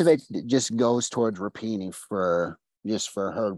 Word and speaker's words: Rapinoe: Yes, Megan Rapinoe.